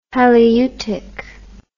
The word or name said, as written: halieutic